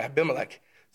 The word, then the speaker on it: Abimelech: Another cool thing, Genesis 21, it actually talks about Abraham separating lambs, seven specifically, from the flock and giving to Abimelech. Yeah, I said that right. Yeah, Abimelech. So he was a shepherd.